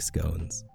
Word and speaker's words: scone: Or I suppose the correct pronunciation is scone, but I simply can't say it. Like, I know that the word scone ends with an E, so the O has to be a long O.